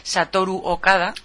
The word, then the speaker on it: Satoru Okada: Sí, por su parte, el director general del Departamento de Investigación e Ingeniería de Nintendo, Satoru Okada.